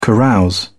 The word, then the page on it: carouse: British English